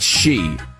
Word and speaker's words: Xi: Xi is pronounced Xi. We're talking about Chairman Xi.